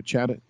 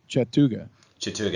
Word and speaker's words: Chattooga: So you're saying you had the 70 mile trail up the Chattooga, and that's right, Chattooga. – Chattooga, yeah.